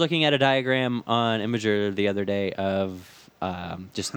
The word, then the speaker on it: Imgur: It's Imgur, everybody.